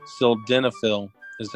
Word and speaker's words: sildenafil: Sildenafil is how you pronounce it.